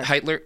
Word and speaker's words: Hitler: I think it's pronounced Hitler. – Hitler, yeah, and he came to power actually in the early 1930s.